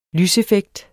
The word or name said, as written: lyseffekt